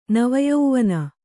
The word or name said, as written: ನವಯವ್ವನೆ